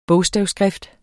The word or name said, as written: bogstavskrift